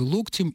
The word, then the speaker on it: локтем: Например, пишет она, коснуться локтем или локтем, как говорит наш инструктор по лечебной физкультуре. Нет, не изменилось. Локтем.